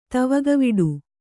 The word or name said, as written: ತವಗವಿಡು